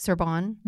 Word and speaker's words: Sorbonne: And then you also pursued a second master's degree in Paris, France, while studying human trafficking at Sorbonne.